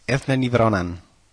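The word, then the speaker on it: Eithne Ní Bhraonáin: se escribe como así, algo así como Eithne Ní Breathnán, en gaélico se pronuncia Eithne Ní Breathnán.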